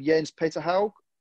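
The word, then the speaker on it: Jens Petter Hauge: Jens Peterhaug. Is that how I say it? – Yes.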